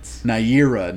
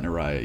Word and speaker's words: Naira: Naira, yeah. I feel like might be how you pronounce her name. – Yeah, did I write it down wrong? Fuck, my bad. – But Naira, not Naraya, yes.